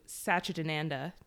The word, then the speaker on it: Satchidananda: And so this week our film is The Watermelon Woman and our album is Journey into Satchidananda.